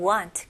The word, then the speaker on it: want: So won't는 좀 더 o 발음이 들어가고 여기 want 같은 경우는 입술을 쓰는 것보다는 턱이 내려가는 느낌으로 want. Want. Very good.